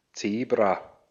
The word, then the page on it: Zebra: /ˈtseːbʀa/